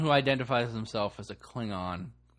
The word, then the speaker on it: Klingon: Still Klingon with the hard G.